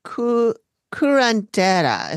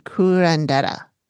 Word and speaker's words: curandera: Amy suggests that they get a curandera is how she pronounced it. It's spelled curandero. That's how it's spelled. But she said curandera.